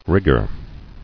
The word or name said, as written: Rigger